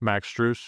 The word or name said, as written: Max Strus